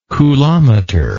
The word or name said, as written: coulometer